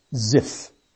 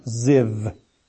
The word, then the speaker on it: Ziv: In the month, there it is said, Ziph. Or in Hebrew, it's pronounced Ziv. V, the V sound is dominant.